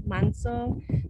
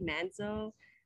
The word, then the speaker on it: Manso: just because it's pronounced like Manso, but like people say it like Manso,